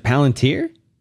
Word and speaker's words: Palantir: Is it Palantir? – I guess so.